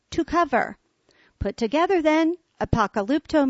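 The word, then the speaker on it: apokalypto (ἀποκαλύπτω): Although the noun is pronounced apokalypsis, the verb is pronounced apokalypto.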